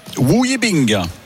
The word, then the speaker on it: Wu Yibing: Espagnol sera opposé à un Chinois, Wu Yibing.